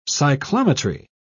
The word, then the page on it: cyclometry: British English